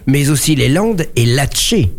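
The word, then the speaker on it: Latche: qu'on dit l'Atché. Enfin, il ne faut même pas prononcer le E, a priori, l'Atché.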